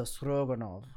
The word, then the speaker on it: Stroganov: So the stroganoffs or stroganoffs, French spelling is actually stroganoff, the normal one with the O-F-F. In the actual Russian pronunciation, it's S-T-R-G-A-N-O-V, not F. So stroganov with the V.